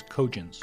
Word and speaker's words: Cogence: Am I saying that right? Cogence. Cogence. – Actually, it's Cogence. – Cogence. Thank you.